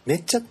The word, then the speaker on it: めっちゃ: めっちゃでもあの東京の人がこう言うときにイントネーションがちょっと違うんですよ。例えばあの何めっちゃむっちゃとか言わないですか。これね間違いなんですよ。めっちゃなんですよ。